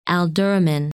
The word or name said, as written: Alderamin